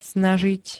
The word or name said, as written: snažiť